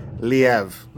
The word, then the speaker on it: Liev: Liv Schreiber. – Liev. – Liev. Well, if you know him well, you call him Liv.